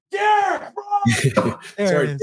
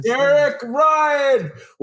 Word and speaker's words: Derek Ryan: Say his name right. It's Derek Ryan. – Derek Ryan.